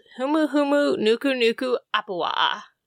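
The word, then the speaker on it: Humu Humu Nuku Nuku Apua (humuhumunukunukuāpuaʻa): And it is called Humu Humu Nuku Nuku Apua.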